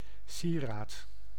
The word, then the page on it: sieraad: /ˈsi.raːt/